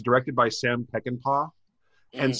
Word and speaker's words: Peckinpah: So, yes, that's how you say it, Peckinpah.